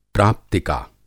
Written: प्राप्तिका

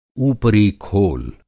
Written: ऊपरी-खोल

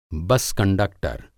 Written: बस कंडक्टर

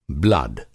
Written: ब्लड

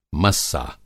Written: मस्सा

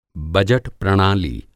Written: बजट प्रणाली

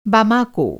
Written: बमाको

